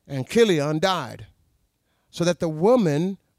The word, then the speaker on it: Chilion: The name of the man was Elimelech, and his wife, Naomi, and the name of his two sons were Mahlon and Chilion. That's how that's pronounced.